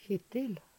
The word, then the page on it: Kjittil: Numedalsmål